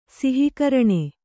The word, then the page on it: ಸಿಹಿಕರಣೆ: kannada